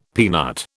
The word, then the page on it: peanut: British English